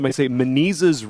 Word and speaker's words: Menezes: I tell them, Menezes rhymes with diseases. Perfect rhyme.